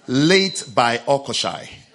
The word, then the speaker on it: Latebiokoshi: If you can't pronounce the second one, it's late by Okoshai. Late by Okoshai. That sounds better, isn't it? Yes. But it's the same as Latebi Okoshi. Yes, but it's late by Okoshai.